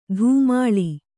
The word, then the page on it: ಧೂಮಾಳಿ: dhūmāḷi